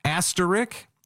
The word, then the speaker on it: asterisk: Asterisk, three syllables, not two, not asterisk, asterisk.